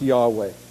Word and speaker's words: Yahweh: Y-H-W-H is the way it occurs in the Hebrew alphabet, probably pronounced Yahweh.